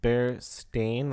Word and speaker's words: Berenstain: The thing is I think it's Berenstain, like, S-T-A-I-N. Sorry, I think it's Berenstain because that's how it's pronounced. If it were E-I-N, it would be Berenstain.